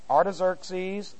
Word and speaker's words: Artaxerxes: A-R-T-A-X-E-R-X-E-S. Artaxerxes the First. Also Longimanus. Longimanus. L-O-G-N.